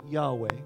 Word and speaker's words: Yahweh: YHWH in Hebrew, and it's pretty much beyond pronunciation. So over time, we've added a couple of vowels in the midst, an A and an E, so we could pronounce it Yahweh.